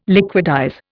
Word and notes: liquidize: Indian

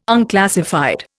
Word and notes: unclassified: Indian

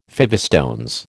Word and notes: fivestones: American English

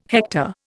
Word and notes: hector: Indian

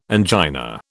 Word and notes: angina: British English

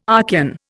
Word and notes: Archean: Indian

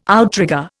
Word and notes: outrigger: Indian

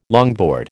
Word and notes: longboard: American English

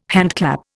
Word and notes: handclap: Indian

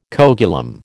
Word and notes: coagulum: American English

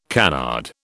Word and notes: canard: British English

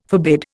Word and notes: forbid: Indian

